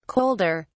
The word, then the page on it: colder: British English